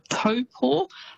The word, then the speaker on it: Taupō: but famous spots including Taupō. So quite often you'll hear that said as Taupō. – Yes. – It's almost how it's spelt, but that Taupō is actually pronounced Taupō.